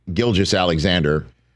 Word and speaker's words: Gilgeous-Alexander: And Shai Gilgeous-Alexander wrapped up one of the great individual seasons in NBA history. – Hard G, soft G.